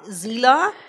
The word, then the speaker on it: Xila: Ich heiße jetzt, und jetzt halt den Schlüppi dreimal fest, das ist ein langer Name, Zila, also X-I-L-A, Zila ausgesprochen auf Englisch.